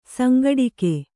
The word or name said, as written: ಸಂಘಟಕಿ